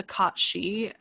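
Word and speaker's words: cat sìth: Yeah, so in Scottish and Celtic mythology in general, there's a creature called the Kachi, spelt cat sith, basically is how it looks, but it's pronounced Kachi.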